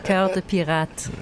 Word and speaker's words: Cœur de pirate: Laughing at me because I butchered the pronunciation again. – Cœur de pirate. – Excellent, de pirate, yeah.